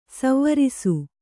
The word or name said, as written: ಸ್ವರಸ